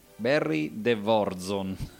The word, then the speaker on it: De Vorzon: Comunque tema dal film The Warriors di Barry von, aspettate, Barry de Vorzon.